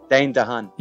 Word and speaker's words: Dane DeHaan: Is it Dane DeHaan? – You said it right. – Lachamet. Timothy Lachamet. – I think it's something DeHaan. – Yeah, Dane DeHaan.